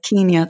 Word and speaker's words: Kenya: You know, I forget that my accent is becoming very, very strange living in America. Kenya, yes, Kenya. They're very keen over there for us to say it correctly.